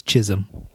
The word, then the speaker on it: Chisholm: That is how you say his name. – What's the H-O-L doing in there then? Like Chisholm. Okay. – You say tomato, I say tomato. He says chism.